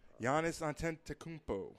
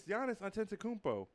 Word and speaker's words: Giannis Antetokounmpo: Giannis Antetokounmpo. – That's not his name. – Yes, it's Giannis Antetokounmpo.